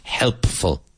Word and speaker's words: helpful: D-E-A-D-L-Y. Eficazmente. Effectively. Carne y hueso. Flesh and blood. Servicial. Helpful. Be careful of pronouncing that P. Helpful. Helpful. Influyente. Influyente. Influential. Influential. Spelled I-N-F-L-U-E-N-T-I-A-L, but influential.